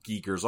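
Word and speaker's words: Giger: So it's actually pronounced Geiger.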